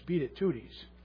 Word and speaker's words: Beatitudes: But I said when I was a kid, I had to learn how to say it, how to spell it. So it was beat it tooties.